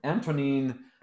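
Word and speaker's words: Antonín: Antonin Dvorak.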